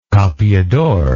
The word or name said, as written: capeador